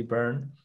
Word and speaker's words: Beirne: It's spelled B-E-I-R-N-E. It looks like Bernie, but it's actually Byrne.